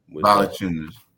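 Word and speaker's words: Valančiūnas: Valanciunas, and I'll never be able to get his name right. – Valanciunas.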